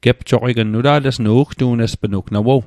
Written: gepjoqign

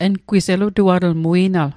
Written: elutuatl

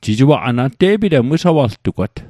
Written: wisawaltugwat

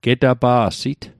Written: getapa'sit

